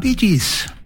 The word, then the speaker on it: Bee Gees: Og så av det at du skriver det slik som du uttaler det, så ble det Bee Gees.